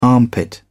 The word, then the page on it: armpit: British English